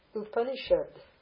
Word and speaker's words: Upanishad: Upanishad. U pa ni shad. See it? Upanishad. Upanishad. Everything's pronounced. U pa ni shad. Upanishad. Upanishad. Pramanam.